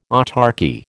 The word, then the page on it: autarchy: American English